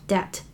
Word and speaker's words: debt: debt 这个词我们之前讲过两三次的样子，d e b t 一个名词表示债务，那个 b 是不发音的，debt，d e b t。所以，get out of debt 就是说不要再欠债了，把债还清。